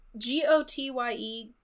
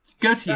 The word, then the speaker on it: Gotye: It's G O T Y E Gotye.